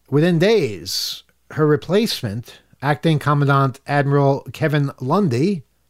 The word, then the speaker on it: Commandant: Former Coast Guard Commandant, that's how you pronounce that, Admiral Linda Fagan, the first woman to lead a branch of the U.S.